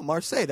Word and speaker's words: Marseille: Oh, Marseille. That's two syllables.